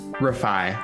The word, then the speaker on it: Rifai: Rifai.